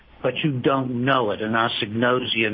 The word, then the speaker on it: anosognosia: Okay, I've been saying agnosia. I've been pronouncing it wrong, I guess. Uh-oh, I'm in trouble. – A-G-N-O-S, agnosia. – Intoxication agnosia. Yeah, I've been... – Anosognosia. – Agnosognosia, okay. – There you go.